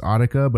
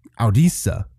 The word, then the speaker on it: Audica: It's A-U-D-I-C-A. I would assume it's Audica, but it could also be a soft C. I don't know. Audisa. – No. It's got to be Audica.